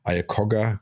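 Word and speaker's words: Iacocca: Und diese, diesen Trend, das hat ja äh, dann äh, ähm, der Ford Manager Ayakogga, wie spricht man den aus? Ayakogga? – Lee Ayakogga. Ja, Lee Ayakogga.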